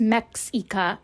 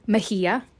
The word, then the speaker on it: Mexia: M-E-X-I-A. Not pronounced Mexica, it's Mejia.